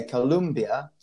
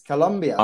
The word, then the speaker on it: Colombia: It's a mistake that a lot of people, especially like English speakers, made when they pronounce Colombia, which is they say Colombia. So the first thing that we need to make sure, John, is that you will say correctly. It's Colombia.